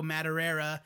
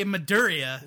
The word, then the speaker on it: Madureira: Well, every time you pronounce it, you say Maduria.